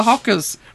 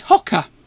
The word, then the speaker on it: Hoccer: Only this is not the Fockers, it's the Hockers. – We're getting away from it now, aren't we? – No, we're bang on point. Hocker. – Hocker, as in HOCCER. That's how you spell it.